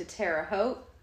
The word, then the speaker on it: Terre Haute: And they went to Terre Haute.